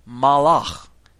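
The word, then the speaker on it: malach (מלאך): The Hebrew word for king is melech, okay? And this has a similar sound to our word for messenger, which is malach. You hear the two? Melech, which is spelled M-E-L-E-K-H, and Malach, which is M-A-L-A-K-H.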